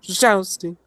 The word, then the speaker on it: Szczęsny: It's got a little bit of an accent that goes up and down very quick. So it's Szczesny.